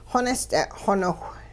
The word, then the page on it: xonist'e'-xoniwh: Hupa